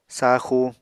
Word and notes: saaxu: Apatani

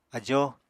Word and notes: ajóo: apatani

